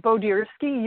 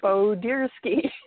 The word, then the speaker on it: Bodirsky: It is Bodirsky. You were pretty close. It's a tongue twister for several people, but Bodirsky. It is Bodirsky.